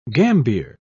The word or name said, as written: gambier